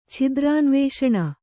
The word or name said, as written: ಛಿದ್ರಾನ್ವೇಷಣ